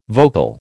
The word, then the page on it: vocal: American English